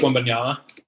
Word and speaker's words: Wembanyama: I think it's Wembanyama.